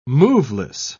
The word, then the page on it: moveless: British English